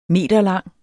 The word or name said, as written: meterlang